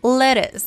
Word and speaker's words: lettuce: Lettuce. Pero veamos si Super Holly tiene este algún comentario sobre esta pronunciación. – Parece que estamos pronunciando e L-E-D-I-S.